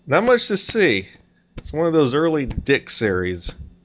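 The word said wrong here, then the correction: DIC: It's pronounced Deek.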